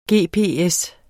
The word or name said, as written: gps